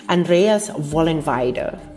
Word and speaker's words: Vollenweider: And Andreas Vollenweider, how do you pronounce his name? Andreas Vollenweider,